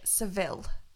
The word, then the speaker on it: Seville: Seville.